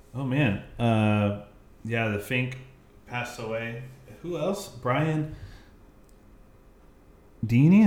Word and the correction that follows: Dennehy: Am I saying that right? – Oh, Dennehy.